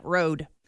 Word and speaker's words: Rhode: Because are you saying Rode like roadkill? – Like Rhode Island. – Hit the road. – It's spelled R-H? Like R-H-O-D-E. – Okay. – Yeah, Rode.